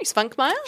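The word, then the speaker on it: Švankmajer: It's a wonderful exploration into, am I saying his name right? Zvankmajer? Yeah, okay.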